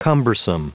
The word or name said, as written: cumbersome